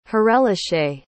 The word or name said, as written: harelache